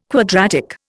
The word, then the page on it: quadratic: Indian